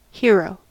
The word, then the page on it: hero: American English